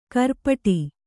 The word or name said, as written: ಖುರಪುಟ